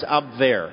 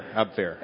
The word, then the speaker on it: Abwehr: He became a double agent for the Abwehr. Is that how it's pronounced? – Not even close. – Okay. – No, it's very close. It's Abwehr. Abwehr.